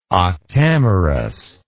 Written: octamerous

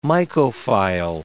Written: mycophile

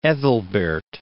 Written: Ethelbert

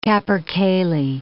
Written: capercaillie

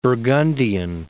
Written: Burgundian

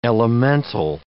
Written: elemental

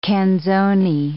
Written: canzone

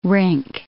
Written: rink

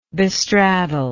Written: bestraddle

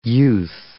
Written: youth

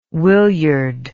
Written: willyard

